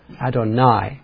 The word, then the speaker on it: Adonai: If you look at the words behind those English words, it's Lord Jehovah, or in the original, it's a word called Adonai, is the way you pronounce it. Adonai.